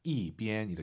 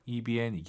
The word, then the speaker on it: 一边: 그리고 숫자 1인데 왜 4성이냐고요? 이边, 숫자 1 뒤에 1성이 오면은 숫자 1은 4성으로 바뀝니다. 이边 이렇게 발음하세요. 이边 이게 아니라.